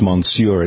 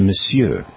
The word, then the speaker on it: Monsieur: But it's not pronounced Monsieur, it's Monsieur.